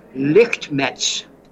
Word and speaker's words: Lichtmesz: 11:15, we hear from Martin Lichtmetz, a German. His Lichtmetz is spelled L-I-C-H-T-M-E-S-Z.